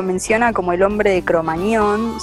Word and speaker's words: Cro-Magnon: Cro-Magnon, se pronuncia, no es con ñ, es con gm,